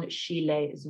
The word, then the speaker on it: Schiele: If you're interested in looking into the Instagram about Egon Schiele that Sophie was talking about, it is at Egon Schiele's Women, and the Schiele is spelt S-C-H-I-E-L-E. So Egon Schiele's Women.